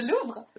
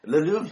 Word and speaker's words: Louvre: And I would go to see the Eiffel Tower and probably go to see Louvre as well. Is that pronounced correct? – The Louvre. – Le Louvre.